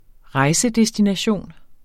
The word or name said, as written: rejsedestination